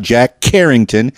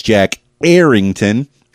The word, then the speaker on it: Jack Errington: Now, you notice I'm putting a lot of emphasis on those syllables because Jack Errington, I don't want you to get confused. It's not Jack Carrington. It's not Jack Harrington. It's Jack Errington of Jack Errington Music.